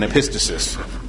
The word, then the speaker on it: epistasis: Both of these words are often mispronounced, pleiotropy and epistasis.